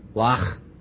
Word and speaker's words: Loch: There's a German word under the illustrator, L-O-C-H. How do you say it? Who did it? Lach. Is that it? Okay, good.